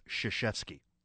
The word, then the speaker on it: Krzyzewski: It's pronounced Krzyzewski, not Krzyzbylewski or whatever.